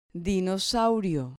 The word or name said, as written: dinosaurio